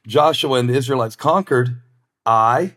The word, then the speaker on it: Ai: Remember Ai? It's spelled AI, not like artificial intelligence, but it's pronounced Ai.